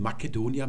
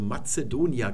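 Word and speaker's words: Macedonia: Das wurde klassisch lateinisch, also noch K ausgesprochen, Makedonia mit C geschrieben. Und nachher, nach dem klassischen Latein, wurde dieses C dann langsam zu einem Z-Laut. Und da hat man dann also Mazedonia gesagt, obwohl es noch mit C geschrieben worden ist.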